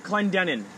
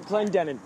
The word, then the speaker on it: Clendenin: Oh, my full name is Kurt Clendenin. It's kind of a three-syllable, it's... Yeah, Kurt Clendenin.